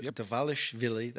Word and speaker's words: Dvalishvili: I think that Dvalishvili, that's how you say his name.